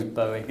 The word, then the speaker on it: Bowie: Bowie himself said it was Bowie.